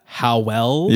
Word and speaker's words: Howell: It's a unique pronunciation. It's Howell. – Yes. – Howell. – Yes. – Howell. – Yeah. – It's like Noel.